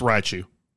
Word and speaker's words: Raichu: Or do you pronounce, like, is there another syllable in there? – It's Raichu. – Raichu? – Raichu. – Raichu. – Raichu. Okay, so like Haichu, but with rye.